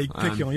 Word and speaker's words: Piccioni: Is that how you pronounce it? – Yeah, Piccioni. Yeah, he only got a concussion though.